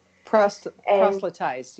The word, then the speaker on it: proselytize: I don't even know how to pronounce this, proselytize? – Proselytize, yeah.